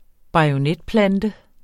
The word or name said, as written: bajonetplante